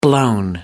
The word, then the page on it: blown: American English